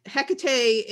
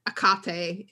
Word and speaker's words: Hekate: I just want to say, you switching the pronunciation, and I just want to say to people that Hekate is the modern American English way of pronouncing the goddess's name. Akate is closer to the ancient yeah.